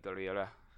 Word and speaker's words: Gurira: How do you say her name? Danae Gurira? – Gurira? – Danae? – Yeah, I would say like Gurira.